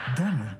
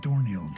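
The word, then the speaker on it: Domhnall: Donald Gleason, not Dorniel Gleason.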